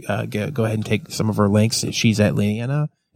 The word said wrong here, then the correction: Linnea: Am I saying it right? Leanna? – Linnea. – Linnea.